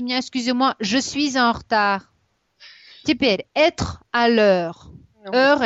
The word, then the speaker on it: retard: Зачеркните себе «р», вот это «э», первую «е» после «э». – «Хта», да, вы сейчас «хтар». – Да, она «хтар», «хтар». «Д» зачеркиваем, «д» на конце не читается.